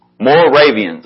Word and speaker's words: Moravians: Moravians, that's how you say it.